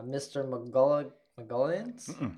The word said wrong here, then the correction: Mr. Mulligan's: No, I said that wrong. – Mr. Mulligan's.